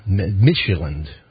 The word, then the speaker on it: Midtjylland: That got Southampton to the playoff round, where they wound up losing to Denmark's Midtjylland. You knew how to pronounce that better than me. Midtjylland. – Midtjylland? – Yeah, Danish team.